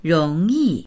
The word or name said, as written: rong2yi4